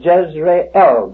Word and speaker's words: Jezreel: And we should pronounce that Jezreel, because the L is a separate syllable and means Jehovah.